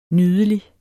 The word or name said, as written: nydelig